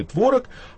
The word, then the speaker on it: творог: Норма диспозитивная позволяет нам говорить «творог» и «творог», а норма императивная говорит, что можно ставить ударение «только звонит», но не звонит.